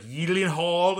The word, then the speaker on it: Gyllenhaal: Did you know that Jake Gyllenhaal, his last name is actually pronounced, like, Yeelenhaal or something like that? – Oh, I totally believe that.